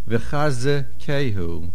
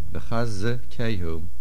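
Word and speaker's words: V'chazekehu (וְחִזְּקֵהוּ): V'chazekehu. There is a dogesh in the zayin. It is a verb of a binyan pi'el. It is a shvono. V'chazekehu.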